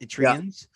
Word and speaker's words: Eritreans: Eritreans? – Yeah.